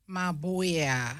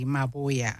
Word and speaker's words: Maboa: Maboya. – Oh, so it's a silent B, Maboya.